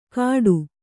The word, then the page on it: ಕಾಡು: Kannada